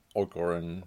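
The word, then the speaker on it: Orgoreyn: It's like Orgorin. How do they say it? – Orgorin. – Orgorin. So it's spelled really weird.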